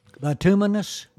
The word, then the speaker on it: bituminous: There's bituminous, I guess you how do you pronounce that? Bituminous coal. – Bituminous? – Bituminous. Is that how you pronounce that? – Yeah. – Yeah, bituminous. Okay.